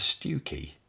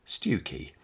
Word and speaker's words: Stiffkey: Continuing on the theme of rather loud female spirits, let's head on over to Stewkey. Yes, it's spelt Stiffkey. But locally, it's the rather lovely seaside village of Stewkey.